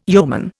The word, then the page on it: yeoman: Indian